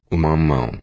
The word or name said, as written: mamao